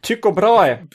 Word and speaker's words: Tycho Brahe: And this week's quote is by Tycho Brahe. I hope I pronounced him correctly. – Tycho Brahe. – Yes. – Yeah, exactly. Because in Germany we pronounce him like that.